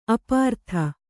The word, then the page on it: ಅಪ್ರತಿ: aprati